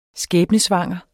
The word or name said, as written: skæbnesvanger